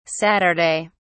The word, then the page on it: saturday: British English